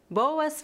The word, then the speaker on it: boas: Primeiro, na palavra boas, lembre-se de pronunciar as duas vogais.